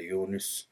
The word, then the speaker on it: Yunus: Yunus Emre, doğru telaffuzuyla Yunus, Yunus Emre bunlardan biri.